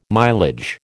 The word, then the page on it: mileage: American English